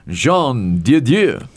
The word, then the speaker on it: Jean Dieu: Jean Dieu.